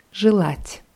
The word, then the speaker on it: желать: Und wünschen heißt auf, Russ auf Russisch желать. – Желать ja, wird mit je vorne geschrieben, je, je, aber wie i ausgesprochen.